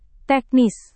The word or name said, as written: teknis